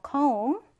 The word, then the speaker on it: comb: 자, 비는 발음하지 않는다라고 했어요. comb.